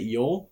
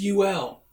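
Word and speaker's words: U L: Is it Yol or Ool Washington? – It was just UL. – Oh. U period L period. UL Washington.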